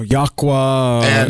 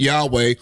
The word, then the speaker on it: Yahweh: And, you know, there are various, I don't want to call them sects, but there are various portions of Hebrew Israelites who pronounce the Father's name Yahuwah. – Or Yahuwah. – And of course, we pronounce it Yahweh with the vowel points.